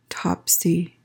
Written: topsy

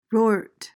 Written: rort

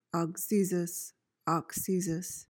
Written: auxesis

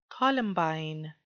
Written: columbine

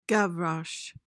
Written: gavroche

